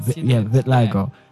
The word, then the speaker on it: vitiligo: So, something that the audience doesn't know that we know here is that has vitiligo. Am I saying that right? – Yes, vitiligo.